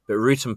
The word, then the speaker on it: Wrotham: But Rootham Park, which for our listeners is spelled Rotham, it's spelled Wrotham,